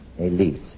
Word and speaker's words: Elyse: Well, I brought a girl named Elise Weber. She's a singer. – Elise Weber. How does Elise spell her first name? – E-L-Y-S-E. – Elise. – Yes, that's right.